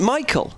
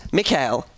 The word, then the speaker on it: Mikhail: After leaving school, however, he discovered that he'd been pronouncing his own name incorrectly and that it was correctly pronounced Mikhail as in Mick Ale, he helpfully puts in brackets.